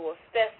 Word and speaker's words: Worcester: Now, if we move to Western Cape, between 2005 and 2006, PANSALP funded the Center for the Deaf in the Worcester system to establish an infrastructure for the development of South African Sign Language.